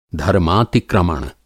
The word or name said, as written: धर्मातिक्रमण